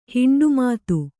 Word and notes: ಹಿಂಡುಮಾತು: Kannada

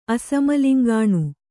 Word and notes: ಅಸಮಲಿಂಗಾಣು: asamaliŋgāṇu

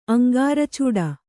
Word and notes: ಅಂಗಾರಚೂಡ: Kannada

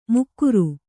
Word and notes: ಮುಕ್ಕುರು: Kannada

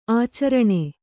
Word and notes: ಆಚರಣ: ācaraṇa

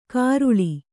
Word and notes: ಕಾರುಳಿ: Kannada

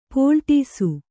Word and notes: ಪಲ್ಲಟಿಸು: pallaṭisu

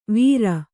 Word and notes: ವಾರೆ: Kannada

